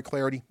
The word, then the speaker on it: Clardy: In the meantime, don't forget, follow me on Twitter, at Troy Clardy. Last name is spelled C-L-A-R-D-Y. C-L-A-R-D-Y.